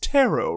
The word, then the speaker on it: tarot: You say tarot like carrot. You know it's pronounced tarot, right, dear? – I did not.